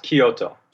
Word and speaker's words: Kyoto: It's pronounced Kyoto.